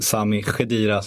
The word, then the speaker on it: Sami Khedira: värjer man Sami Khedira som vi fått lära oss av mm. Nemrut Kurt att han ska uttalas.